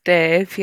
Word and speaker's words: Damh: It's Dave, yeah. It's spelled D-A-M-H, though.